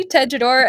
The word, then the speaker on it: Tejedor: My name is Katie Tejedor, and you can find me on Twitter at KRR Tejedor, which is spelled TEJEDOR.